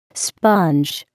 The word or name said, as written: sponge